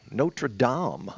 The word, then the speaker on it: Notre Dame: But, you know, the beautiful thing is, Truman, you pronounce that expertly correctly, Notre Dame. – Mm-hmm. – Avon doesn't say it that way.